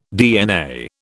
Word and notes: DNA: British English

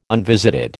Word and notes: unvisited: American English

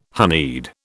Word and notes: honeyed: British English

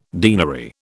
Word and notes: denary: British English

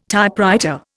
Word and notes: Typewriter: Indian

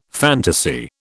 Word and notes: fantasy: British English

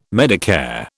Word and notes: Medicare: British English

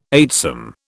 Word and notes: eightsome: British English

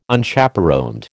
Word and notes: Unchaperoned: American English

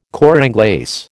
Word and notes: cor anglais: American English

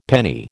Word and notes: penny: American English

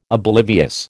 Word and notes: oblivious: American English

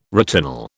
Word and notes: retinol: British English

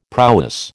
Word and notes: prowess: American English

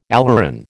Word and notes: aleuron: American English

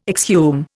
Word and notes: exhume: Indian

